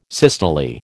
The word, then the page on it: systole: American English